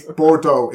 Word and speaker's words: Bordeaux: It's like, it's Bordeaux. It's two syllables, yet 15 vowels.